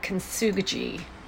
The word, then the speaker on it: kintsugi: I think it's kintsugi. Kintsugi? Kintsugi. Kintsugi. It's K-I-N-T-S-U-G-I, kintsugi.